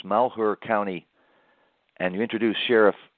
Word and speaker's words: Malheur: Well, it sounds like he isn't so sure at first, but and you're right, you pronounce it right. It's Malheur County, and he's the sheriff in that county.